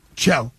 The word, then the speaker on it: Chell: Chell or Shell? – Chell. – Chell. I said it correctly this time.